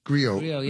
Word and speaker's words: griot: Griot, yeah.